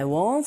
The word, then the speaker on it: won't: 이때 W-A-N-T와 W-O-N apostrophe T 이걸 구분하실 때 W-O-N apostrophe T는 won't 조금 더 동그랗게 입을 모아서 발음하시면 원하다라는 동사 want와 구분하실 수 있어요.